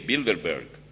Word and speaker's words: Bilderberg: Se escribe B-I-L-D-R-B-E-R-G, Bilderberg.